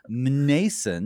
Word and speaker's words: Mnason: here's a name for you, M-N-A-S-O-N, Mnason. Nobody will pronounce it right for the rest of his life, but Mnason, he's a Cypriote.